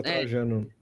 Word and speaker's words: Trajano: ainda estava a Luísa Trajano, falei certo? Da... – É, tava Luísa é, Trajano. É, Luísa Tra... é, não lembrava se era Trajano mesmo.